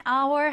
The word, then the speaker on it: hour: 자, hour 이 단어를 딱 보면 h로 시작하니까 hour이라고 읽어야 할 것 같지만 첫 번째 h는 무음, 소리 나지 않습니다. 그래서 hour, hour이라고 읽어주시면 돼요. 우리 one hour 하면 한 시간이 되는 거고요.